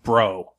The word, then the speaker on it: Breault: His name is Henry, and his last name is spelled B-R-E-A-U-L-T, and it's pronounced bro.